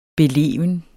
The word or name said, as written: beleven